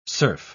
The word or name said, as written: surf